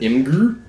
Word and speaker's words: Imgur: And, like, Reddit, you have to post pictures to Imgur. Is that how you pronounce it? – Imgur.